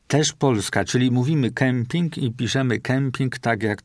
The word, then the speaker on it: kemping: Bo tak, wyraz się ortograficznie i fonetycznie, czyli wymawianiowo, dawno spolonizował, czyli powtórzę, piszemy kemping, mówimy kemping, a czasami jak ktoś napisze camping, to ma ochotę też mówić camping, a nie kemping, czyli tak trochę udając angielszczyznę.